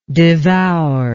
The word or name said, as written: devouringly